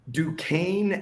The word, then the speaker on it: Duquesne: Duquesne at West Virginia. – That was the correct pronunciation.